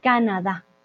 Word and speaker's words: Canadá: Entonces no decimos Canadá, no decimos Canadá, no, decimos Canadá. ¿Vale? Esa tilde la hace todo el cambio.